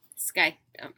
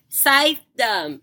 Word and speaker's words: Scythedom: Scythedom. Oh my god. Dana. – How do you say it? – Scythedom. – Scythedom. – Scythes in a scythedom. It's that simple.